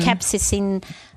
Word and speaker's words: capsaicin: Mm, capsicum? Yeah, capsaicin is how it's pronounced.